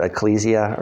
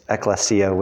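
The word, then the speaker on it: ekklesia (ἐκκλησία): So that comes from the Greek word ekklesia. Some people pronounce it ecclesia or something like that, but it's ekklesia, which basically just means assembly of people.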